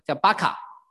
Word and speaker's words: 巴卡: 流泪谷啊，希伯来原文的发音叫巴卡啊，所以其实有的就翻成巴卡谷。